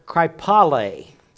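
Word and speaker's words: kraipale (κραιπάλη): Dissipation is the word kraipale, K-R-A-I-P-A-L, long E. When you see an eight on there, that's an eta that is used in the Greek, and they have more than one E. They have an epsilon. Anyway, I'm not going to give you a Greek class, but that's a long E that's there.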